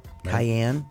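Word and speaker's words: Kyann: It's spelled K-Y. K-Y-A-N-N. Cayenne. – Reminds me of the pepper.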